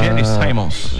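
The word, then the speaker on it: Gene Simmons: É o Gene Simons.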